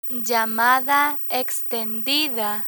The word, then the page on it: llamada extendida: Spanish